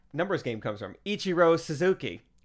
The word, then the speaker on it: Suzuki: Because me and my girls like to play Suzukis. No. – No. – Ichiro. – No. Suzuki. Rhymes with Pazuki.